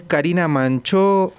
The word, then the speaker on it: Karina Manchó: Su nombre es Karina Manchó.